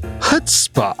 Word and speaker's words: chutzpah: Actually, I don't think it's pronounced chutzpah. That's how it's spelled. Oh. I think it's pronounced chutzpah. – Oh, it's chutzpah. – On this thing, it says chutzpah. It rhymes with foot spa. Chutzpah.